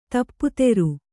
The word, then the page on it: ದಪ್ತರು: daptaru